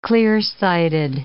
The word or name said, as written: clear-sighted